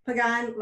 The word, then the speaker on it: Pagán: It's Pagán, but I say when I'm introducing myself, Pagán like Pagán. So it's Pagán like Pagán, the French pronunciation, Pagán.